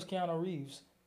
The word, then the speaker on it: Keanu Reeves: I didn't know that was Keanu Reeves.